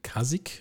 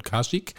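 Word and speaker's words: Kazik: Der stammt von der Band Kasik, wenn ich es richtig ausgesprochen habe. Habe ich nicht, von Kasik.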